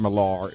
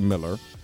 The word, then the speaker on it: Millar: But last week, I kind of went on a mini rant about Mark Miller, and I found out his name is not Millar. It is actually Miller, despite how it's spelled.